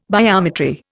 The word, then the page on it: biometry: Indian